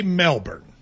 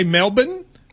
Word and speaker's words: Melbourne: So you're saying Americans say Melbourne. – Yes. – But apparently, and I didn't know this, Australians say Melbourne. – Melbourne apparently is what they.